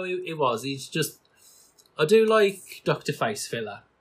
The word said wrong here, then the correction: Facilier: I can't pronounce his name. – Facilier. – Facefiller. – Facilier. – Shadow Man. – At least try. Facilier.